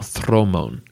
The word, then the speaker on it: dromon: By this time, the fleet would be made up of dromon warships, as they're spelt, or thromon, as they would be pronounced in Greek.